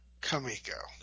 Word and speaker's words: Comico: You idiots, whoever founded this company, the comic company, felt somehow the English pronunciation was Comico. So that is why, much as it still pains me to this day, in deference to this publisher, I will pronounce the name of the company in the ridiculous, delightfully ridiculous way it was presented, it turns out, as Shutter Comico.